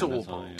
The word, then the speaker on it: Albarn: Oh, it's Albarn. Yeah. – She's Albarn. It's got to be, hasn't it? Yeah. – Yes. – You've met him. – Demon Albarn. – It's Albarn.